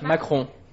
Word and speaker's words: Macron: Macron.